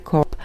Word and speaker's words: corps: CORPS, to je ten vojenský útvar, čte se COP, a CORPS, což je ta mrtvola, a čte se CORPS.